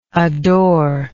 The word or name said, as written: adoringly